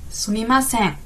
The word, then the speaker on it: Sumimasen: Die andere Variante, die sehr oft vorkommt, das ist eben das Sumimasen. Sumimasen. Vorsicht bei der Betonung. Masen. Sumimasen.